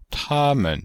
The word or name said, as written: ta1men5